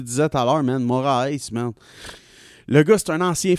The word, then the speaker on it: Moraes: Un combat de Marlon Moraes. Euh, c'est ça, c'est comme ça qu'on le prononce, monsieur.